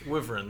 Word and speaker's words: Wyvern: Wyvern, that's it.